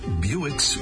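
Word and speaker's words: Bewick's: Well, that expansive coverage seems logical since part of its current common name sounds like that of an automobile. The bird is the Buick's wren. Well, this This Buick, though, is spelled B-E-W-I-C-K.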